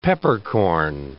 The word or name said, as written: peppercorn